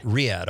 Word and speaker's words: Riyadh: I'm saying it right. Is it Riyadh? – Riyadh.